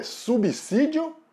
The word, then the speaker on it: subsídio: O S depois do prefixo sub sempre vai ter som de S mesmo, não de Z. O S só vai ter som de Z se ele tiver entre vogais, que não é o caso da palavra subsídio.